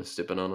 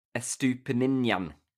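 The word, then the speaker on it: Estupiñán: I'm calling him Estupinan and I'm sticking to it. – Estupinan. I want a bit of a connecting in the middle, please.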